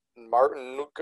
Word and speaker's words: Martinook: Yeah, so one of their hockey players, Jordan Martinook, it's spelled M A R T I N O O K.